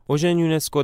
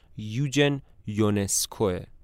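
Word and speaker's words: Eugen Ionescu: اوژن یونسکو در سال 1909 در رومانی به دنیا میاد. البته تلفظ صحیح اسمش به زبان رومانیایی یوجن یونسکوه.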